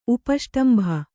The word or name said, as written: ಉಪಷ್ಟಂಭ